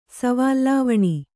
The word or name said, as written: ಸವಾಲ್‍ಲಾವಣಿ